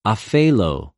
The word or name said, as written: ὀφειλω